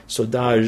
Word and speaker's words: saudade: So you've got this wonderful saudade, is that how you say it? Saudade. – Saudade.